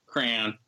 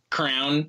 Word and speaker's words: crayon: Crayon. Crown. – Crown. Call it crown. Crown. Crayon. It's crayon. called. It's a fucking crayon. crayon. Or There's or two syllables.